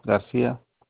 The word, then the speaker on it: Garcia: Fernando Garcia. – Spell that last name for me, sir. – G-A-R-C-I-A.